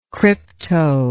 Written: crypto

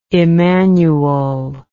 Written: emanuel

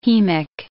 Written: hemic

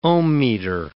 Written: ohmmeter

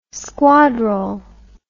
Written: squadrol

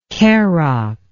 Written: Kerak

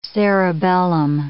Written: cerebellum

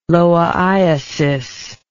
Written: loaiasis

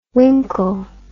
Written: winkel

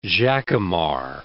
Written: jacamar

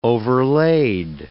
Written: overlaid

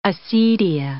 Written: acedia

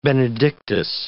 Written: Benedictus

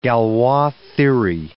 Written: Galois theory

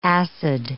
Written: acid

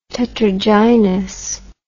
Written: tetragynous

